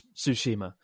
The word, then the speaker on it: Tsushima: Someone asking how to pronounce how to pronounce Tsushima, and uh, it's kind of like tsu, tsu, tsu, Tsushima, Tsushima. Right? There's a subtle difference between Tsushima and Tsushima. – Just mm. enunciate the T, Tsu, Mm.